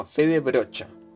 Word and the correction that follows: Frede Brocha: No, Frede Brocha. Sí, así se, se pronuncia, perdón, le estaba leyendo mal, perdón.